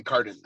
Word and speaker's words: Carden: Exactly, Carden.